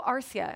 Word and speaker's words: Arcia: So we have Jankowski leading off, Will Myers, Yanhervis Solarte, Dickerson in cleanup, Schimpf, Sanchez, Oswaldo Arcia.